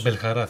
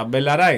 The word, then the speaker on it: Μπελαρά: Όχι, δεν τονίζεται, δεν υπάρχει Μπελχαρά. Μπελαρά είναι. Κρίμα. Το άλλο είναι ένα παπάκι είναι κάπου ενδιάμεσα. Το μάθαμε, το έχουμε πει και εμείς λάθος.